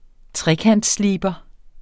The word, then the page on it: trekantssliber: ˈtʁεkands-